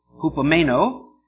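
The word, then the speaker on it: hupomeno (ὑπομένω): It's H-U-P-O-M-E-N-O, hupomeno. H-U-P-O-M-E-N-O, hupomeno.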